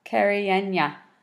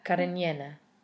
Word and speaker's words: Cariñena: How would you say that word at the bottom of the label? – Cariñena. – Cariñena. – Oh, okay. I was wrong.